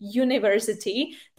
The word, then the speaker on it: university: A naopak u slovíčka university, i přestože se to píše university, tak první se čte j, university, tak první, čte, eh, tak proto používám e, takže a e, university.